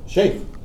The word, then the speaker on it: Sheikh: Oh, that's how you pronounce it? – Sheikh. – I didn't know you pronounce it, okay. – That's all American. – Americans, yeah. – Sheikh. K-H is Kha.